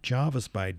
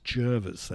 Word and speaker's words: Jervis Bay: It's not Jarvis Bay, darling. It's Jervis. That's the correct pronunciation because I come from the local area.